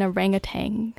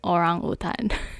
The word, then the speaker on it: orangutan: If you ever go to Malaysia and see an orangutan, now you know how to pronounce it in Malaysia. Orangutan.